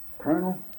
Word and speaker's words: colonel: Oh, you might pronounce it as it sounds about the same as the hard nut of a walnut. – Colonel. – Yeah. – Yeah, that's right.